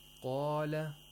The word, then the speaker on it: قَالَ: Qaf is always pronounced with tafkhim because it's a letter of istirla. So, قَالَ. Only the qaf is pronounced with tafkhim.